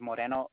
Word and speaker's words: Moreno: And my last name is Moreno, M-O-R-E-N-O.